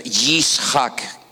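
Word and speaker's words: Yitzhak: Nosotros decimos Isaac, pero en hebreo es Yishak.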